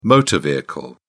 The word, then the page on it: motor vehicle: British English